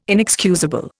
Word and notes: inexcusable: Indian

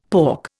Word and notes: pork: Indian

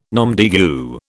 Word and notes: nom de guerre: British English

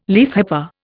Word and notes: leafhopper: Indian

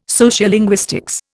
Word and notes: sociolinguistics: Indian

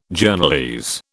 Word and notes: Journalese: British English

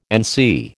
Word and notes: NC: American English

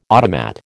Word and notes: automat: American English

